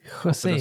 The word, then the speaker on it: José: José. das ist äh, richtig. José. Ich glaube, das wird so ausgesprochen.